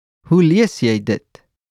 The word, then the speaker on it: Hoe lees jy dit: In Afrikaans, How do you read this is Hoe lees jy dit? Let's break it down by syllable. Who, lies, yay, dit. Let's hear it once again. Who lies ye dit?